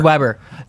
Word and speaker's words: Weber: The way you Centimeter just, Peter Wiederweer. – No, that's not it. – I don't know. Weber. – It's Weber.